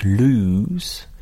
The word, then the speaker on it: loose: Et alors, il ne faut pas le confondre avec loose, qui s'écrit L2O-S-E, qui se prononce loose, qui veut dire desserrer, lâcher.